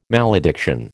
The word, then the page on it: malediction: American English